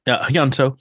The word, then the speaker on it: Hyunseo: It's Hyunseo, right? Right. That's how you pronounce it. – Hyunseo. Yeah.